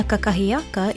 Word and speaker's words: kakahiaka: Aina kakahiaka is how we say breakfast in Hawaiian, and that is our Hawaiian word of the day.